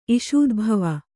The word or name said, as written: ಇಷೂದ್ಭವ